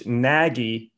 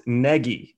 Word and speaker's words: Nagy: Please, everyone, especially sportscasters, stop calling the new coach Nagy. He told the Sun-Times it's pronounced Nagy.